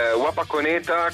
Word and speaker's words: Wapakoneta: Ecco Niccolò, io partirei proprio dal titolo del vostro nuovo singolo, Wapakoneta, non so se la pronuncia è giusta, un comune degli Stati Uniti d'America nello stato dell'Ohio, ma cosa rappresenta per voi? – Esatto, Wapakoneta, come hai detto tu,